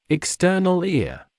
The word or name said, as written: external ear